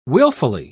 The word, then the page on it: wilfully: British English